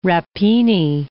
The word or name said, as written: rapini